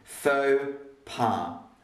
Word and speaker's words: faux pas: Faux pas, We don't say the X and we don't say the S because it's a French word. Faux pas.